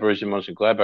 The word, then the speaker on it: Borussia Mönchengladbach: And she told me that, Borussia Mönchengladbach.